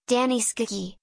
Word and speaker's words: Gianni Schicchi: Gianni Schicchi, Italian pronunciation, Danny Schicchi, is a comic opera in one act by Giacomo Puccini to an Italian libretto by Giovacchino Forzano, composed in 1917-18.